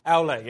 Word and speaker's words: Aule: Aule, yes.